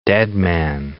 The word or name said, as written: deadman